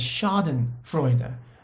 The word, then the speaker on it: Schadenfreude: And the Germans call this, I think the pronunciation is Schadenfreude, when you love to see other people fall.